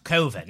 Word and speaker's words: Coven: Turns out Coven is an acceptable pronunciation. – Oh man, that guy. That is that true? – That, yeah, I looked at that years later. – It's actually pronounced Coven.